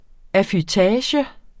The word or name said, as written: affutage